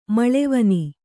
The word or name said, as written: ಮಳೆವನಿ